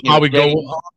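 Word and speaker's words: Mauigoa: I mean, you got Francis Magua. I probably butchered that last name. – Francis Maui Goa. – Maui Goa, okay.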